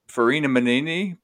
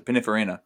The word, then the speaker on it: Pininfarina: Y'all, this incredibly weirdly named F1 car that I like, Farina Manini, the Sigma. What is it? – Pininfarina? – Is that how you pronounce it? Pininfarina.